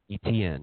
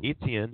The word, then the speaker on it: Etienne: You know, I get Trevor Lawrence and how do you say his last name? – Etienne. – Etienne. – E-T-N. – There you go. – E-T-N.